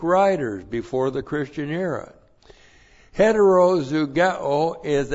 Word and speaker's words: heterozugeo: And the key word here in verse 14 is heterozugeo. That's the key word. Looks like that. Oop, got to put the H in there. H-E-T-E-R-O-Z-U-G-E long O. Hetero zugeo, a hapax legomenon.